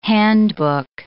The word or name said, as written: handbook